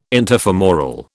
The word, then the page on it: interfemoral: British English